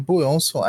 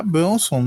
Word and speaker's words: Buronson: Eu tava mandando áudio no WhatsApp, aí eu falei Buronson, aí me toquei que isso é a pronúncia em japonês, com aquele inglês porcaria que eles falam. É Bronson mesmo. E aí eu fui dar uma pesquisada na Wikipedia e descobri que é isso mesmo.